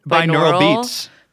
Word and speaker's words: binaural: Bioral? Is that how you say it? – Binaural beats.